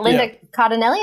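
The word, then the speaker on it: Cardellini: Oh, I just wanted to say we finally get Linda Cardinelli. Is that how you say her name? – Sure.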